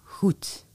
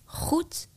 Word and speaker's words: goed: Depending on the region, you can hear G or a more soft one, like here in Noord-Brabant. Then it sounds more like goed. Goed, goed.